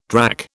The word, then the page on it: drack: British English